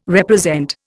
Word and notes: represent: Indian

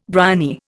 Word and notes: bryony: Indian

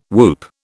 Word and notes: whoop: British English

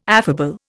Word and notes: affable: Indian

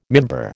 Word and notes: mimbar: American English

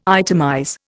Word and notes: itemize: Indian

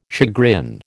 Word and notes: chagrin: American English

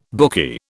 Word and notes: bookie: British English

